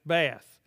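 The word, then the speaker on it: Beth: It's B E T H, but it's pronounced Bath. Everybody got that?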